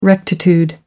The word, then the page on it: rectitude: American English